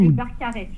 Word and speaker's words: Barcarès: B-A-R-C-A-R-E-S. – A-R-E-S. Ça se prononce comment ? Le Barcarès ou... – Le Barcarès.